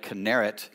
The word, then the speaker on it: Kinneret: It's called Lake Kinneret in Hebrew, or this is how the Israelis pronounce it.